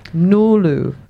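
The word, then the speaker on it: Ngulu: Nulu. – Nulu means voice in Woiwurrung language. – It's N-G-U-L-U, silent G.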